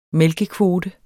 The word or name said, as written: mælkekvote